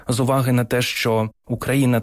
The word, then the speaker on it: Україна: Тут теж ця назва пройшла шлях розвитку від наголосу Україна, який потім перемістився відповідно до інших, за прикладом інших просторових назв, скажімо, Буковина. Наголос переміщується. І так само і Україна.